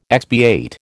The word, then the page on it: expiate: American English